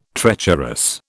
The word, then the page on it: treacherous: British English